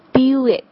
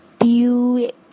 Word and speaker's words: Buick: 应该是 Buick，在这个 B 的后面，不要忘记这个 Y 的这个音哈。 Buick，其实就像 beautiful 的那个感觉一样哈，Buick。